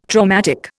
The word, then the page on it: traumatic: Indian